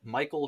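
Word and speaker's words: Mykle: It's Michael Shortino, spelt phonetically. So it's M-Y-K-L-E, short, you know.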